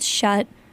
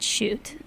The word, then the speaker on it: Schutt: S-C-H-U-T-T. Pronounce shut, not shoot.